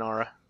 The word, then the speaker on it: Ara: No, it's always been Ara. – I've just always been mispronouncing it.